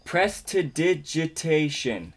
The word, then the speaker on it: prestidigitation: Is actually pronounced? – Yes. – Prestidigitation. – Yes. Rolls off the tongue. – Yeah. – You nailed it.